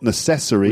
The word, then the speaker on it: necessary: The point here is that you've got necessary, Now, those unstressed syllables, mm-hmm. the vowel sounds become very weak. – Yeah. – So it's not kind of necessary, but necessary. – Yes. – A lot of schwa sounds.